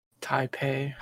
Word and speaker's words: Taipei: Uh, so that's another piece, as well as the um, Tapai Game Show. Uh, I believe that's how you say it. The Taipei Taipei Game Show um, has been canceled, uh, and that was supposed to take place in China. Taipei. Taipei. – Yeah, there we go.